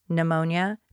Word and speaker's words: pneumonia: pneumonia, I'm sorry, that's pneumonia, the P is silent, and in the case of Blanche, Sophia alludes, chlamydia, gonorrhea, and syphilis, sexually transmitted infections.